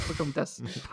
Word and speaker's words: Pocahontas: Eh bien, comment tu dis ? – Pocahontas, pardon. – Pocahontas, ouais. On prononce pas le H en français. – Ah, ouais, c'est vrai que c'est pas un mot anglais.